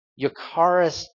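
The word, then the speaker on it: eucharistia (εὐχαριστία): And in Greek, it's pronounced, if I can do this, eucharistia. Eucharistia.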